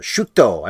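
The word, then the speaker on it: shuuto: It's spelled, by the way, S-H-U-U-T-O, a Japanese word, and I think it's supposed to be a screwball.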